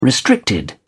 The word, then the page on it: restricted: British English